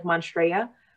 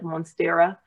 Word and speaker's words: Monstera: I've always said it wrong. I used to say like Monstrea. No, the way it spells like Monstera.